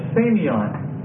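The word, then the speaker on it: semeion: Sign or miracle, it's semion.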